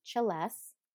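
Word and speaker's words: celesta: And so it's actually played on a celesse, which is spelled like Celeste.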